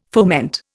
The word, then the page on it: foment: Indian